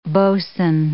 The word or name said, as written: boatswain